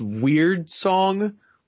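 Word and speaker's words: Wyrd Song: It's called, I guess it's pronounced Weird Song, W-Y-R-D Song, and it's a brand new open world RPG from Bethesda, Obsidian Veterans, Bethesda alone.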